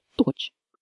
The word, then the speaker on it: Deutsch: And Deutsch ends with TSCH, which makes a ch sound, as in church. So Deutsch is pronounced Deutsch.